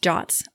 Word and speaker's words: JWT: And the more recent projects that I've been on, we've used JWTs, or they're pronounced JOTs, I found out, which is really surprising. I don't know why, but that's a thing. – What? This doesn't feel true. – It's JWT, but it's pronounced JOT, J-O-T.